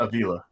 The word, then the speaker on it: Avila: Did I say that right? Do you know how to say it? – Avila. – Avila. Okay.